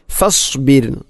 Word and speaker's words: فاصبر: റായിനെ കനപ്പിച്ചുകൊണ്ട് ഒരിക്കലും അങ്ങനെ പറയരുത്. ഫസ്ബിർ, റായിന് തർക്കീക്കാണ്, അഥവാ നേർപ്പിച്ചുകൊണ്ട് ഉച്ചരിക്കണം ഫസ്ബിർ. അതോടൊപ്പം സ്വാദിൻ്റെ ഉച്ചാരണവും, അതിനെ സീൻ ഫസ്ബിർ ഫസ്ബിർ അല്ലെങ്കിൽ ഫസ്ബിർ എന്ന് പറയരുത്.